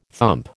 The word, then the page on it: thump: American English